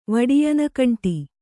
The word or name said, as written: ವಡಿಯನಕಂಟಿ